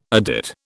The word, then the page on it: adit: British English